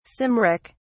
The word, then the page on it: cymric: British English